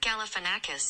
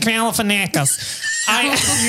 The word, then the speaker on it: Galifianakis: Zach Galifianakis. – Oh, there you go. Right. It's Akis. Zach Galifianakis. – You just keep the same vowel sound.